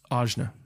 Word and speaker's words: Ajna: Ajna. – Ajna. A-J-N-A.